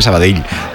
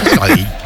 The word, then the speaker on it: Sabadell: El cachondo de Kiki Lisa dice, pronuncias muy bien Sabadell. Dice, mis respetos. Sabadell. – A mí me lo decían mucho cuando estaba en Primera Federación, porque yo también decía Sabadell, que es como se dice.